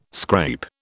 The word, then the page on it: scrape: British English